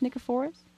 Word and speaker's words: Nicephorus: Can you talk about, do you pronounce it Nicephorus? – Nicephorus. – Nicephorus, thank you.